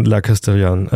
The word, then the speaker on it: La Castellane: La Castellane. Habe ich das richtig? Castellan, ich glaube. – Ich glaube, also ungefähr so schreibt man es zumindest, das heißt, du wirst ja nicht so weit. Das E hinten ist wegen Scheiße. Fehlenden, genau, ist stumm, weil kein Alexander Gül oder Alexander Graf.